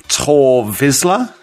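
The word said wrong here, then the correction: Tarre Vizsla: It's Tarr Vizsla. – Tarr Vizsla. – I'll give you that. Yeah. You knew the answer. – It was the pronunciation. It was an A with a line over it.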